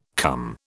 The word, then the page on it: cum: British English